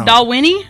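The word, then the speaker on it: Dalwhinnie: Doll Winnie? Is that how you say it? – Yeah, I believe so.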